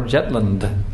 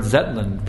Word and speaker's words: Zetland: And he was preaching to one British lord, Lord Jetland. Or actually Lord Zetland.